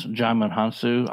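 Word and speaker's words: Djimon Hounsou: Jaimin Hansu. – Yes, Jaimin Hansu.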